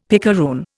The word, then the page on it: picaroon: Indian